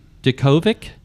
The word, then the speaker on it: Djokovic: So the next story, and I kind of mentioned that a minute ago, is the Serbian tennis superstar, Novak, and I hope I pronounced this right, Djokovic, I think. – That's close enough. – Djokovic.